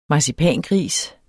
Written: marcipangris

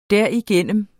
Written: derigennem

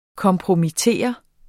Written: kompromittere

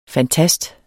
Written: fantast

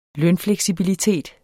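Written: lønfleksibilitet

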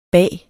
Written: bage

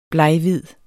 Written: bleghvid

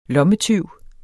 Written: lommetyv

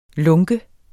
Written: lunke

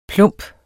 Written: plump

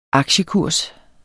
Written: aktiekurs

